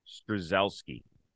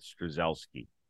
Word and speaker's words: Strzelecki: I believe that's how you say it. Strzelecki. That's what I'll go with, Strzelecki.